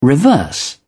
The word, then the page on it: reverse: British English